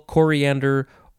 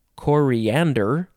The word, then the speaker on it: coriander: Coriander, you can emphasize the first syllable, coriander, or you can emphasize the third syllable, coriander.